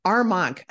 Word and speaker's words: Armonk: Armar Armonk.